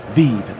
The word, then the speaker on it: Vive: It is pronounced the Vive, and I only say that because I've heard people from Valve pronounce it that way.